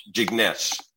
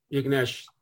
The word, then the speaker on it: Jignesh: Am I saying that right? – Jignesh. – Well, that sounds like there's an N in it. Is that how it's pronounced? – Yeah, Jignesh. You got it with a D? – Oh, yes. Okay. Yes, there is an end in it.